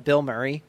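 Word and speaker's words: Bilmuri: It's called Bill Murray, but not spelled like that. It's B-I-L-M-U-R-I.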